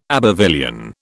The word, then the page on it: Abbevillian: British English